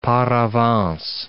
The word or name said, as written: par avance